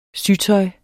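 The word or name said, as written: Sytøjet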